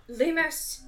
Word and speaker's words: Lumos: Lumos.